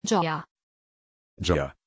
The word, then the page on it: Gioia: German